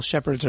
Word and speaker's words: Shepherd: Okay, and we probably, maybe spell your last name just in case, because not all shepherds are spelled the same way. – But actually, it's just like German shepherd. – Just like German shepherd. – S-H-E-P-H-E-R-D.